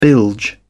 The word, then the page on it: bilge: British English